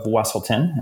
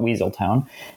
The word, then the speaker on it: Wesselton: So I get to be the Duke of Wesselton, and I'm going to say it correctly, unlike the characters in the play that call it Weaseltown.